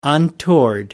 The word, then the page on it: untoward: American English